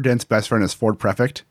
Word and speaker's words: Prefect: For the longest time, for some reason, my brain did not read Prefect. It read Perfect. Even though it's spelled Prefect and it's the name of a car, I would always call him Ford Perfect.